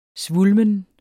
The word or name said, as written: svulmen